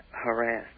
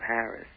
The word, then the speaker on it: harass: Yeah, I just wanted to first start off by saying I think it's harass, not Harris.